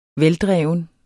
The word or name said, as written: veldreven